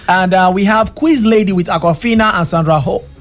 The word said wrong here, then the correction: Sandra Oh: To pronounce that O-H name, they do Sandra Oh. Yeah. – Oh, that's her name, Sandra Oh. – Yeah, Sandra Oh.